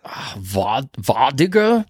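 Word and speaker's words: vardøger: is have you ever heard the term Vardager? And I'm not sure if I'm pronouncing that right or not. Scandinavian. – Vardager? – Yeah, that's a better pronunciation than me.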